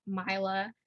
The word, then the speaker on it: Myla: Yeah, so my Instagram is Myla. And spell that, I feel like most people can't spell that. So MYLA_LI.